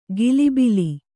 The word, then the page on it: ಕಿಲಿಬಿಲಿ: kannada; kilibili